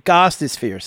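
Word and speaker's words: Gostisbehere: Gossisphere. Really Shane Gossisphere. Shane Gostisphere. Say it correctly. – I got you. Shane Gostisphere. – You're still messing it up. Gostisphere.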